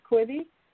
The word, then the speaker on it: Quibi: I mean, I think they're still mired, and I could be wrong, in the lawsuit that they had with, I believe the pronunciation is Quibi.